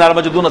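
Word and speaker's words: ہرمجدون: اس سرزمین کو کہا جاتا ہے آر مجدون, آر میگاڈون اور جو بھی آپ اس کا انگلش تلفظ کرنا چاہیں, لیکن آر مجدون اصل لفظ ہے.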